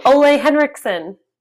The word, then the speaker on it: Ole Henriksen: Ole Henriksen. – Oh, Ole Henriksen.